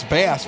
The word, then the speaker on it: Bass: And then you can pronounce Bass, B-A-S-S, as bass like a bass guitar.